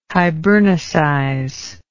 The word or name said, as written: hibernicize